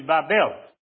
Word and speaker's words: Babel: And in the story of the Tower of Babel, the name is changed to Babel, which is the right way to say that, which sounds a bit like the Hebrew word for confusion.